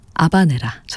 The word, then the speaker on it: 아바네라: BJ 오페라 까르멘 가운데 하바네라, 아바네라 전해드렸습니다. 고티에 카피송의 첼로, 파리 치임버 오케스트라의 연주였습니다. 원어 발음에는 또 아바네라라고도 또 저희가 소개해드리곤 하는데요.